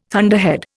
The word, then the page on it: thunderhead: Indian